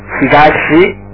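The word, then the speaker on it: higashi (東): And finally, the east is pronounced as higashi, higashi.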